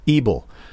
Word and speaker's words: Ebel: It's Ebel, as in eagle.